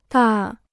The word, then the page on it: 大: dà